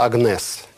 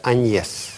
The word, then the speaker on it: Agnès: Пишется Агнес. Читается Аньес.